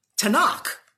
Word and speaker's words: Tanakh: That's why you get the pronunciation Tanakh. Because it's the Torah, Nevi'im, and Ketuvim.